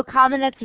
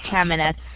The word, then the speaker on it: Kamenetz: Kamenetz is probably more correct from a Slavic point of view, but we say Kamenetz.